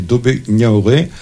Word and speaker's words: Dobé Niaoré: Dobé nous embarque dans un sacré voyage, c'est Dobé Niaoré. Je ne sais pas si on prononce comme ça.